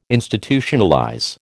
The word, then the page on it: Institutionalize: American English